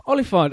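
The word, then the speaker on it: Olyphant: Is it Oliphant? – Oliphant. I always think of Lord of the Rings, yeah, the big big elephants.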